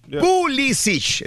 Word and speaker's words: Pulisic: Pulisic, Pulisic. Pulisic, es correcto.